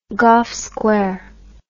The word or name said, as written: Gough Square